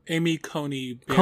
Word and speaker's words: Amy Coney Barrett: did I say that right, or something like that? – Amy Coney Barrett.